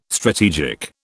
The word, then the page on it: strategic: British English